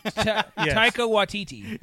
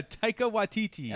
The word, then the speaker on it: Taika Waititi: Takiti Waititi. – Taika Waititi. – Yeah, Taika Waititi.